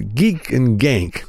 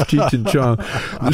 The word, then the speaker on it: Cheech and Chong: That's you couldn't pronounce Cheech and Chong. So many of the black clubs couldn't pronounce Cheech and Chong. It was Geek and Gang, Chi and Chan. They'd mess it up.